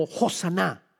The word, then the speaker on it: Hosanna: y se debe pronunciar como Hosanna.